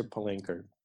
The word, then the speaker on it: Palanker: So I'm LinkedIn and Instagram at the same handle as Pasha Palanker, P-A-S-H-A, P-A-L-A-N, like November, K-E-R.